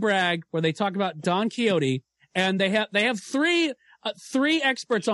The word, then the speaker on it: Don Quixote: I listened to an In Our Time podcast about Don Quixote. I had no idea that across the pond, it is pronounced, in Britain, rather, it is pronounced Don Quixote.